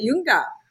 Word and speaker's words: Junge: You know, it could absolutely be both. If you are German, you're going to pronounce it Junge.